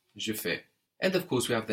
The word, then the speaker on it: fais: So we have je fais, no, F-A-I-S, and you do is tu fais, spelled the same, F-A-I-S.